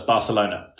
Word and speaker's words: Barcelona: That's Barcelona for the rest of the listeners. – No, it's Barcelona. You have to pronounce it that way.